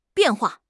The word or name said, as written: bian4 hua4